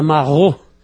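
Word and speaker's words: maraud: Existe uma palavra francesa, marrou, que se escreve M-A-R-A-U, porque A-U em francês se lê O. – Marrou.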